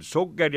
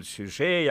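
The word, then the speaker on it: Suger: Selle täiuslikuks väljenduseks on kahtlemata Abte Sugeri, vahel kirjutatakse see ühe giiga, vahel kahe giiga Sugger, siis hääldatakse seda küll Sugger ja küll Sügee ja kuidas tahes, ükskõik.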